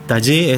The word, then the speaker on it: Tajy: Taller se escribe T-A-J-Y, esa es la manera de escribirse. Taller es Lapacho en guaraní, y bueno, venimos con este proyecto hace 13 años, 14 ya cumplió, sí, 14.